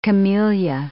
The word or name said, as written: camellia